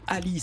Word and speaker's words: Alie: Et votre actualité, ce dernier album, je ne savais pas comment le prononcer. Ali, ça s'écrit A-L-I-E et une tournée.